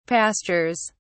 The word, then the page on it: pastures: British English